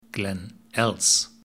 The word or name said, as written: Glen Els